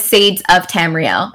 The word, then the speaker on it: Sadesoftamriel: I go by Sades, but my channel, my channel name is Sadesoftamriel.